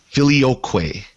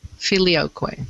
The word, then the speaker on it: Filioque: Filioque. – Filioque.